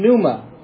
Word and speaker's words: pneuma: And it's made up of two Greek words, theos, which from which we derive God, and then pneuma, has silent P on there, which is translated as wind or breath or spirit within the appropriate context.